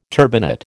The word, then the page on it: turbinate: American English